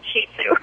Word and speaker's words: Shih Tzu: Actually, it's a Chinese word, and the pronunciation is Shih Tzu.